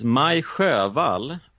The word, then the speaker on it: Sjöwall: Well, it is Maj Sjöwall och Per Wahlöö. – Per Wahlöö. – Yeah, it's the Ö sound.